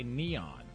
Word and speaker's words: N3on: It's spelled N, the number 3-O-N. N3on. – Yeah, but he goes by Neon.